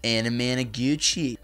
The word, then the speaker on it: Anamanaguchi: There's that dude off of their album, Endless Fantasy, that says Anamanaguchi. That's how he pronounces it, so that's how I pronounce it.